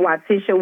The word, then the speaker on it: Wytisha: You can go to Amazon and type in Waitisha Williams. It's spelled W-Y-T-I-S-H-A, Williams with an S.